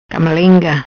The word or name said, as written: Camalenga